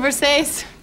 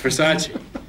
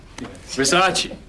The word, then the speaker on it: Versace: It's a Versace. – It's Versace. – What? – Versace. It's pronounced Versace.